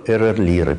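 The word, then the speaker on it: RR Лиры: Если правильно говорить, звезды типа Эрерлира, переменные звезды типа Эрерлира.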